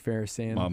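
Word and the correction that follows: Feinsand: Mark Finesand. – Finesand. Said his name wrong, yeah.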